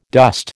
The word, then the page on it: dost: American English